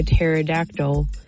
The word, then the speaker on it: pterodactyl: Why can't you hear a pterodactyl in the bathroom? Because the P is silent.